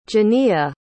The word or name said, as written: Janiyah